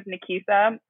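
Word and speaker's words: Nakisa: I am, so my first name is Nikisa.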